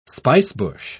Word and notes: spicebush: British English

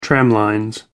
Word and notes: tramlines: American English

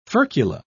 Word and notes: furcula: British English

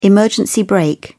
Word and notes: emergency brake: British English

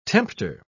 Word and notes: tempter: British English

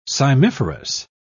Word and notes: cymiferous: British English

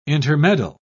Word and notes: intermeddle: British English

